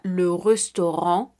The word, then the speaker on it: restaurant: Este sonido se pronunciará como una O normal, como en español. Estamos hablando de algunos ejemplos de este sonido, como la palabra le restaurant, le restaurant,